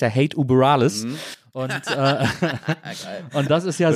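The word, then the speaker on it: Hate Über Alles: Das gut ausgesprochen. – Ja, so muss man das doch aussprechen, weil es ist ja ein Gruß quasi zu der kalifornischen Punk-Legende überhaupt. – Genau.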